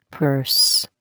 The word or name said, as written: purse